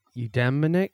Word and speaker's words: eudaimonic: And you mentioned the eudaimonic, how do you pronounce that? – Eudaimonic well-being.